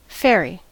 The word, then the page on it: fairy: American English